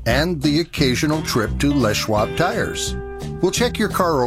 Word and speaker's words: Les Schwab: This is for a company, a local tire company called Les Schwab, and it is pronounced that way, although it looks like it should be Les Schwab, right? L-E-S? – Yeah.